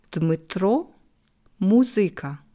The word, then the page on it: Dmytro Muzyka: slow